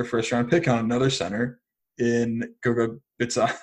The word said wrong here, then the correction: Bitadze: I don't know why I tried to say his name. – Batadze, I believe. – Goga Bitadze.